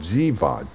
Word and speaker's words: Jiva: The soul is called, this soul that gets up here, is called Jiva, J-I, long I-V-A.